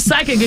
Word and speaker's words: psychic: So you're right, because when we see psychic, it's spelled P-S-Y, right? – I couldn't tell you. – Yes, yeah, this, yep. – So the P yep. is silent.